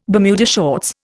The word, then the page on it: Bermuda shorts: Indian